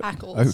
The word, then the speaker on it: Ackles: Ackles.